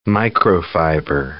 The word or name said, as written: microfiber